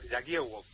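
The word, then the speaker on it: Jagiełło: Exactamente, aquí se llama Yagiewo, una especie de, letra, una doble L partida que se pronuncia como W.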